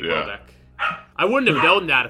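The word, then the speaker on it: Houellebecq: Wait, is that how you say his name? – Welbeck? – Yeah. – That's how you say it? – Yeah, how did you say it? – I was saying, like, Hollenbeck. – It's just Welbeck.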